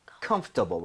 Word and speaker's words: comfortable: 뭐냐면 여러분들 중간에 OR 보이죠? – 네. – 이거를 발음을 거의 안 하는 사람도 엄청 많습니다. 그래서 그냥 comfortable, right?